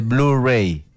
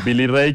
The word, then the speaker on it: Billy Ray: O sea, cuando Billy Ray estaba más joven y definitivamente Dolly Parton también, cantaban juntos porque no se dice Billy Ray, se dice Blu-ray. – No. Billy Ray, que es.